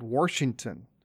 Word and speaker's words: Washington: Washington. A lot of people want to stick in that R when they're pronouncing Washington, or instead of announcing, "I'm going to wash the clothes, I'm going to wash the clothes."